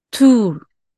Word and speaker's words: tool: ということは、どうやって読むえっと、ツール、トゥール。